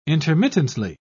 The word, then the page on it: intermittently: British English